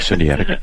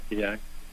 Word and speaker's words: Sinaitic: But and then you talked about the Sinai or whatever covenant. – Sinaitic, however you pronounce that. Thank you, Sinaitic.